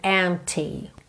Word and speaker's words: empty: The days would all be empty. Empty, não se preocupe em pronunciar esse P, ok? Não precisa dizer empty, empty. Em, já fechou aqui o lábio no M? Já tá feito P, ok?